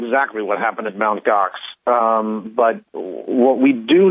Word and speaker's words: Gox: about Mount Gox, G-O-X.